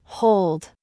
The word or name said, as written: DAD